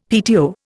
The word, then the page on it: PTO: Indian